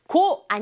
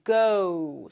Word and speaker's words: go: 고 아니고요 친구들, go. 선생님 입술 작아지죠? go. Go. 이렇게 발음해야 된다는 거.